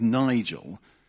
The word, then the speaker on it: Nigel: And I'm continually challenged by this scripture, because the problem when your name begins with Nigel, it rhymes with negative.